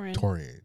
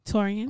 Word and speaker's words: Taurean: But I even liked how Molly and Taurean, that's how you say his name? – Taurean.